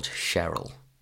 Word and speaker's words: Cheryl: She goes Cheryl like a chair. – Cheryl, yeah. – But it's really confusing because you don't know Cheryl or Cheryl. Like, I mean, it's spelt exactly the same. – How do we know? It's spelt with a ch, not a sh.